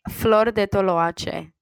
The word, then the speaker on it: Flor de Toloache: So the first song is Long Gone Girl by Flor de Toloache. Did I pronounce that right? – Flor de Toloache. – Yes.